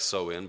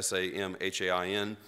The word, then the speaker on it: Samhain: The ancient Druids of Europe celebrated what they called the Vigil of Samhain. Doesn't look like Samhain, but that's the way you pronounce it, Samhain, to honor their god Samhain, who they considered the Lord of the Dead.